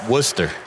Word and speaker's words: Worcester: Worcester. – Yeah. Worcester. It should be like W-U-S-T-E-R.